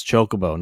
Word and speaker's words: Chocobo: It's Chocobo, not Chocobo. – Either way. – They're made out of Choco, not Chalk. – Either way, this game is literally Mario Kart. – It is. It's Chocobo Racing.